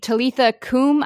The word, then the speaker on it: Talitha Kum: And he fully believes that she's going to get up and walk, or like that phrase, Talitha Kum. I don't know how to pronounce it. Is that right? – That's right.